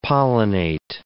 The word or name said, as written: pollinate